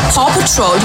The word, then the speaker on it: Paw Patrol: Nee, der ist in dem Alter, das Puppetour gerade das Beste ist. Wie der Mitarbeiter von dir jedes Mal... – Wie du das gerade ausgesprochen hast. Das heißt Paw Patrol. Du hast gerade Puppetour.